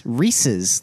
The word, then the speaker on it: Reese's: There's an apostrophe S. Reese's. Like Reese Witherspoon.